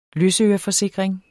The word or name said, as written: løsøreforsikring